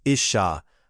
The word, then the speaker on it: Isha: Woman equals Isha, pronounced Isha, from Ish, pronounced Ish.